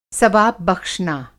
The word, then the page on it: सवाब बख़्शना: ثواب بخشنا